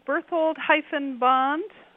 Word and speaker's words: Berthold-Bond: It's Annie, and the last name is Berthold-Bond. – Berthold. – It's spelled Berthold hyphen B-O-N-D. – Like James Bond.